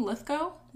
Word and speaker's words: Lithgow: Lithgow? – Yeah.